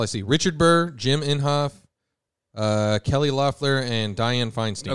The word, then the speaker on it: Inhofe: Looks like Jim Inhofe. – Yeah, Jim Inhofe.